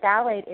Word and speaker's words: phthalate: But it's spelled D-I-E-T-H-Y-L is diethyl, and phthalate is P-H-T-H-A-L-A-T-E, and it's sometimes abbreviated D-E-P, diethyl phthalate.